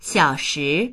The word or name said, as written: xiao3shi2